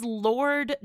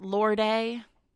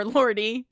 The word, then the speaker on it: Lorde: So, but I would like to, can we get a final, it is Lord, not Lorde, or Lordy. – Yeah, it's Lord. – It's Lord. – It's, I think it's Lord. Right. – I feel like that E is silent. – It's silent. It's an unnecessary E.